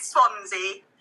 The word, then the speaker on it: Swansea: Now, talking about feeding, you're currently the senior research officer with an emphasis on infant feeding for Swansea University. Hopefully I pronounced the name of the university correct. What is the role and responsibilities in your position? – You did pronounce it correctly, yeah, with Swansea.